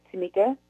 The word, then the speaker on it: Tamika: And my name is Tamika. It's T-A-M-I-K-A. And last name is Newhouse, spelled like it sounds, N-E-W-H-O-U-S-E.